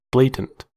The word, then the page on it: blatant: American English